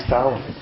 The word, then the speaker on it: Tolland: Did I say it right, Kevin? Tolland? – Yes, you did. – Nice. See, it is Tolland.